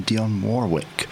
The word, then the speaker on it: Dionne Warwick: So that was written by Bacharach and David, Bert Bacharach and Hal David, primarily for the artiste that was their muse, Dionne Warwick, that's the correct pronunciation, Dionne Warwick, in 1963.